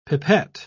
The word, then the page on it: pipet: British English